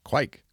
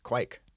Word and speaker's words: kveik: Quique? – Quike. – Quike. I was close. It's spelled K-V-E-I-K.